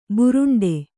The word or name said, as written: ಭೂರುಂಡಿ